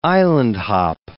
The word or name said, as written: island-hop